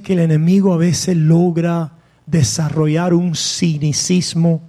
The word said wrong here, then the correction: cinismo: ¿se dice así? Cinicismo, cynicism, cinismo, ok, thank you, gracias, necesito esa corrección.